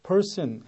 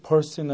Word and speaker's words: person: 중간에 나올 때도 사람이란 단어를 person 해서 R 발음이 들어가는 게 미국식 발음이죠. 그런데 person 할 때 그 R이 죽어서 발음이 되는 게 또 영국식 발음입니다.